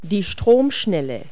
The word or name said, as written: rapids